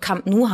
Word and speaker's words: Camp Nou: Und es hat sich dann halt, ja, es war eine leichte Revolte sozusagen, dass es weiterhin einfach katalanisch ausgesprochen wurde und Camp Nou heißt.